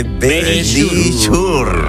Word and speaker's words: Verisure: Nuestros amigos de Verisure.